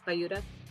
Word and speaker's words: Bayuda: I always want to call it Buddha because it's spelled like Buddha, but it's Bayuda.